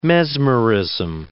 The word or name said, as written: mesmerism